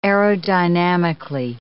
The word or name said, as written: aerodynamically